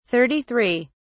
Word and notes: 33: British English

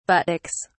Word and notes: buttocks: British English

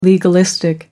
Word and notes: legalistic: American English

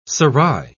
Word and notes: serai: British English

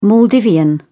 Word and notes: maldivian: British English

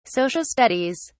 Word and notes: social studies: British English

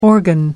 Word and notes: organ: American English

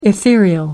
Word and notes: ethereal: American English

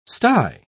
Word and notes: stye: British English

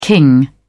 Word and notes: king: British English

